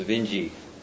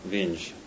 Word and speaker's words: Vinge: Actually, I've never heard Werner Vinge's name pronounced, but I believe it's pronounced Vinge, not Vinge.